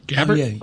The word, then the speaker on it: Gabbard: Gabbard.